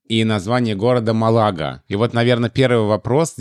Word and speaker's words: Малага: Ну и «Малага», да, то же самое. Правильно, Малага. И я надеюсь, что в русских словарях будет Малага тоже.